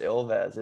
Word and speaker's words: Ilves: So I was going to call it Eels. – Yeah. It's pronounced Ilves. It's like a bobcat or a lynx.